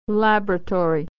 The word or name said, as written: laboratory